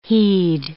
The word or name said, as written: he'd